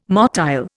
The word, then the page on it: motile: Indian